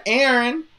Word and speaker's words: Eren: Then we have Aaron Celian, or I just learned his name today. It's the Turkish version of Aaron, E-R-E-N.